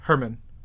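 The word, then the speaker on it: Germán: Oh, it's Herman? – Yeah. – Have I been saying German? – Herman Peralta. – Herman Peralta. – Remember Herman, who used to work here? – Yeah. – It's spelled with a G.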